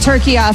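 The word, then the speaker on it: Türkiye: I will be saying it turkeya from now on.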